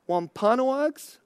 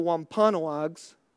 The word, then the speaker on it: Wampanoags: With the aid and instruction, I don't know how to pronounce this, because Wampanoags, I think that's how you pronounce it. The local Native American tribe, the Wampanoags, the pilgrims were able to plant corn and other crops in the spring, establishing the basis for their survival.